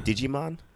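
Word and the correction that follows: Djimon: Jaimin. – Like Simon. – You don't pronounce the D.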